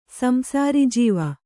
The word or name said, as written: ಸಂಸಾರಿಜೀವ